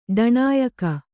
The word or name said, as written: ಡಣ್ಣಾಯಕ